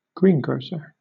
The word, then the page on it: greengrocer: British English